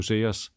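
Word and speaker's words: Seres: Ja, det er så med S. – Ja. – Men ikke desto mindre, så udtales det jo Sears.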